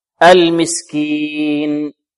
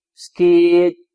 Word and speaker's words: المسكين: طيب، ولكن فيها جزء مكمل، جزء مكمل كذلك، فنقف عليها بغنة هنا ناقصة، المسكين، لا ننطقها هكذا كما يقرأ البعض، ولا تحضون على طعام المسكين، مسكين، مسكين، هكذا يبتر ماذا؟ غنتها أو يبترها.